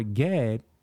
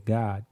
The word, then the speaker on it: Gad: The word God or Gad, G-A-D, that's what they say, that's how it's spelled, but it's really said God.